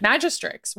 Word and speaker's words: Magistrix: Magistrix. Why wouldn't you pronounce the X? It's like dominatrix.